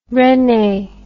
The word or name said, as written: Resnais